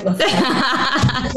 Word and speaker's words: LaFleur: Matt LaFleur. Did I say that right? LaFleur.